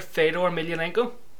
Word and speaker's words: Fedor Emelianenko: Great example, in MMA, there's a fighter, Fedor Emelianenko, right? – Well pronounced.